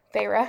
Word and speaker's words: Feyre: Or do you think it's Feyre? Fairy? – Feyre. – Feyre. – Feyre. Mm-hmm.